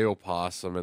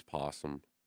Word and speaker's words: opossum: Americans sometimes say opossum, and they just the O is silent. It's possum.